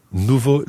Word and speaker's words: nouveaux: Now, nouveau in the singular is spelled N-O-U-V-E-A-U, and in the plural... exactly the same, but with an X on the end. N-O-U-V-E-A-U-X. But it sounds exactly like the singular.